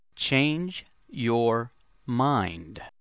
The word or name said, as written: change your mind